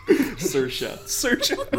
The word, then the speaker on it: Saoirse: Saoirse. Rhymes with inertia.